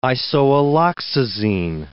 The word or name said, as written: isoalloxazine